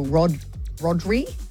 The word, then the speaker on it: Rodri: Firstly, because Barcelona has just signed Spanish midfielder Maestro Rodri.